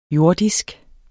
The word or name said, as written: jordisk